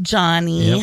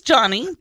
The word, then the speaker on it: Joni: J-O-N-I. – J-O-N-I. By looks the way. Like Joni, but yeah. It's pronounced Johnny. Yep. Her dad wanted a boy. That's the story she tells. So it is Johnny, but J-O-N-I.